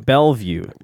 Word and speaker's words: Bellevue: Bellevue.